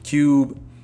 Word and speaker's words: Qube: And like I said, if I'm pronouncing this correct, it's spelled K-K. God damn it. Q-U-B-E. Which I'm pretty sure I am. So, Cube.